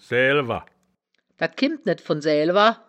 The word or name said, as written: saeaelwer